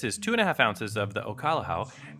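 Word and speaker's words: Okolehao: And then 2.5 ounces of Ocalahau.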